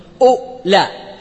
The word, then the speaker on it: أولى: هذه النطق يا شيخ عندنا ثلاث حروف في النطق، أولى، الهمزة مضمومة وبعدها اللام وبعدها الألف، لكن تكتب بعدها واو.